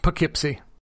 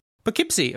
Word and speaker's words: Poughkeepsie: How do you pronounce that? – Poughkeepsie. – Poughkeepsie, okay.